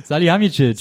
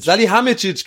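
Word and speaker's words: Salihamidžić: Also, Hassan Salih, äh, Sali, Salih, sag mal, wie der heißt. – Salihamidzic. Salihamidzic, genau, genau. So, deswegen kam ich gerade durcheinander. Ich hab's falsch gesagt.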